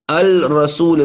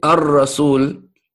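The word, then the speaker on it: Ar-Rasul (الرسول): Al-Rasul is hard to say. Ar-Rasul, that's how it's said in Tajweed, right?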